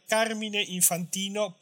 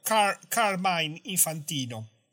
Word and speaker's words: Carmine Infantino: Yo uso el nombre Carmine Infantino porque sería la forma italiana. Con ese nombre debe tener origen italiana. Pero en muchos programas norteamericanos he escuchado pronunciarlo como Carmine Infantino.